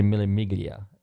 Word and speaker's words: Mille Miglia: Some people say Mille Miglia. The G is pronounced, and I think it's Mille Miglia.